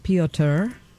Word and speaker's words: Piotr: which I found out is spelled Piotr, which is P-I-O-T-R.